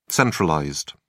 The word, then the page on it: centralized: British English